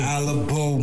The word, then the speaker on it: ALABO: I'm gonna keep butchering the first name because I keep Hold on, say it one more time. – ALABO. – ALABO Munch. I don't know why I can't get that down for nothing.